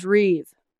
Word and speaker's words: Reeve: My last name is Reeve. – Spell it, please. – R-E-E-V-E.